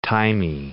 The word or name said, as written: thymey